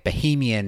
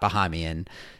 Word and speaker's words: Bahamian: This is actually the second mispronunciation that I've had in recent weeks, but our editor Ingrid has just informed me that I should be calling it Bahamian, not Bahamian.